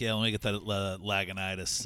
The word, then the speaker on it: Lagunitas: I probably didn't even say the name right, like everybody else. – Lagunitas. – Lagunitas, yeah. – Lagunitas.